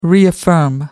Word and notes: reaffirm: American English

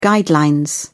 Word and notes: guideline: British English

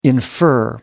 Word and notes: infer: American English

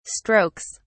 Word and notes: strokes: British English

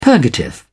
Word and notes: purgative: British English

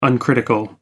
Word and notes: uncritical: American English

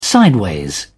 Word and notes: sideways: British English

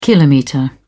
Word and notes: kilometre: British English

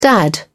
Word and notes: dad: English; British English

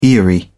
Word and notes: aerie: British English

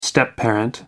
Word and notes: stepparent: American English

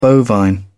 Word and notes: bovine: British English

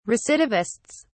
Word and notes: recidivists: British English

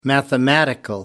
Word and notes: mathematical: American English

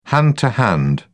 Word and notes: hand to hand: British English

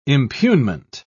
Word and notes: impugnment: British English

